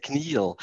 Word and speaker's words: CNIL: So I made a mistake for many years, and I called them the CNIL, and it's actually pronounced the CNIL, which is very difficult for English speakers to say the CNIL.